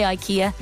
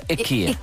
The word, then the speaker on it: IKEA: No, they're IKEA. – IKEA. Which is the Swedish like pronunciation.